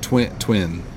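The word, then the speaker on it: Twen: It's T-W-E-N, Twin.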